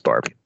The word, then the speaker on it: Barbee: Tony Barbee. – Mm-hmm. – Which is the correct pronunciation of his name. I think Barbie. – No, it's just on these. – It's not. It's just Barbie.